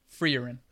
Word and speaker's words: Frieren: Frieren.